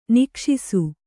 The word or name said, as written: ನಿಕ್ಷಿಸು